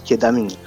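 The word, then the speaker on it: ketamine: c'est vrai que kétamine, prononcé à l'américaine, ça se prononce un peu plus kédamine. Le T se prononce plus comme un D.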